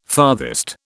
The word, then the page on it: farthest: British English